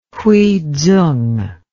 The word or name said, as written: Hui Tsung